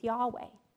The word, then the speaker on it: Yahweh: Probably, traditionally, the original pronunciation for God's name was Yahweh.